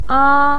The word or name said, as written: a1